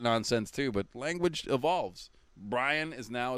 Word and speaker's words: Brian: Because if we still said it the same way you say Ryan, like Brian, then of course that's two syllables. I'm not an idiot. But nobody says it that way anymore. – People say Brian. – Brian. One syllable. Brian.